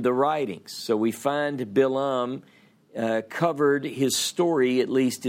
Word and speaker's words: Balaam: Okay, so last week we talked about this seer by the name of Balaam, B-A-L-A-A-M in English, pronounced Balaam in Hebrew.